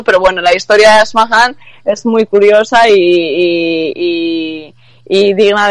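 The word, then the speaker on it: Asmahan: Sí, que busque por eso, pues bueno, se escribe Ashmahan sí. Correcto, lo has pronunciado correctamente, bueno.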